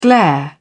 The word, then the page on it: glare: British English